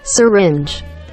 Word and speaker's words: syringe: Syringe.